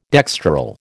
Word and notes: dextral: American English

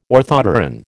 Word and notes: orthopteran: American English

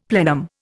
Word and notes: plenum: Indian

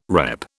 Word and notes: repp: British English

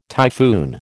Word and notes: typhoon: American English

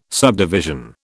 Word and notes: subdivision: British English

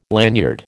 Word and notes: lanyard: American English

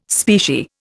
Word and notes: specie: Indian

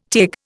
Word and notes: Taig: Indian

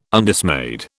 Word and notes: Undismayed: British English

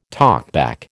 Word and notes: talkback: American English

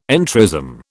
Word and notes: entrism: British English

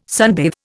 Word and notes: sunbathe: Indian

